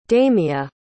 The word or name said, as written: damia